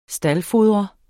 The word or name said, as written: staldfodre